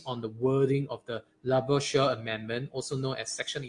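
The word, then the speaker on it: Labouchere: Let's see how it sounds like. Right. Laboucher. – Yeah. – Okay. Sure. Sounds really Frenchy.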